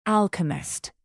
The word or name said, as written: alchemist